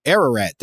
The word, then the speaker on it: Ararat: And the Bible describes Noah landing his ark at a place called Mount Ararat. I don't know how to say this. Ararat, there you go, which was thought by Europeans of Blumenbach's time to be on the modern Turkish-Armenian border.